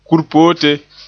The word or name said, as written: kurpote